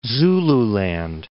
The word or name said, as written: Zululand